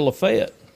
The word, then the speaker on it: Lafayette: And when I was at Ole Miss, I worked at a church out in the county, and I pronounced Lafayette. – Mm-hmm. – And man, they corrected me quick. They're like, "You are wrong." – That's Lafayette, Louisiana.